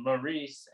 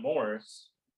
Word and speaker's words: Maurice: And I want to make sure this is called Maurice and not Morris. But a lot of people say it both ways.